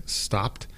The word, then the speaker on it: stopped: Por ejemplo, las palabras stopped, como que yo paré, tú paraste, stopped. Lo que ellos tienen escrito aquí es muy bueno. Obviamente se deletrea como S-T-O-P-P-E-D, S-T-O-P-P-E-D, pero suena más como S-T-O-P-P-T, S-T-O-P-P-T, stopped, porque es una sola sílaba.